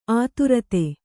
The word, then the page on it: ಅಧರಿತ: adharita